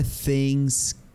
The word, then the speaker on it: things: How are things going? Esta palabra things, que se traduce como cosas en inglés, se pronuncia como una Z, con una Z al inicio.